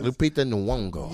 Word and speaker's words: Lupita Nyong'o: I know how to say her name. – Yeah. – Lupita Nyong'o. – Yes.